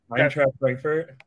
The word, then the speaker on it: Eintracht Frankfurt: Did I say that right? Oh, my. – Eintracht Frankfurt? – Ah, there we go.